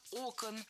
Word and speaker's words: окон: Как раз-таки через глаза ребенка, как там это описано, что он просыпается, даже шторы яркие сняли с окон, потому что пришла пора другая совсем. – Не окон, а окон. В единственном числе окно, окна, окно, окном, ударное окончание. А во множественном ударение переносим на первый слог.